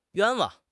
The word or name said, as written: 冤枉